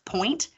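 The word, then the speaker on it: Puente: I'm pretty sure her last name is pronounced Point, but it's P-U-E. N-T-E.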